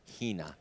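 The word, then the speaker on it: hina (ἵνα): Often it's just one little Greek word, three letters, pronounced hina.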